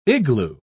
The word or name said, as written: igloo